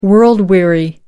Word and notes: world weary: American English

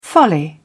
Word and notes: folly: British English

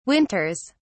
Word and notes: winters: British English

